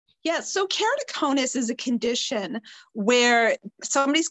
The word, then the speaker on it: Keratoconus: And you were recently featured on the Alliance of Vision and Vision Research's World, I'm going to mispronounce this word, my apologies, Keratoconus Day Congressional Briefing. – Keratoconus, yes.